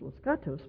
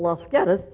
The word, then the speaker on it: Los Gatos: Well, I was born and raised in a small town in California called Los Gatos, which if you speak Spanish, you know I didn't say it correctly because it was settled by Midwesterners, and it should be pronounced Los Gatos, but Midwesterners decided to call it Los Gatos, so that's what it's called.